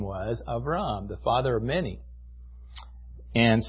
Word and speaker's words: Avram: I think that would probably be the way that the Hebrews would pronounce it, Avram or Abram.